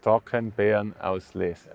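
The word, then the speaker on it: Trockenbeerenauslese: how, do, how do you say it in uh, German? Uh, in Germany, uh, it's Trockenbeeren Auslese.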